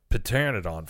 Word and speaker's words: Pteranodon: Uh, uh, then they have Pteranodon flyers, which looks like pteranodon flyers. – Pteranodon. It's a silent P.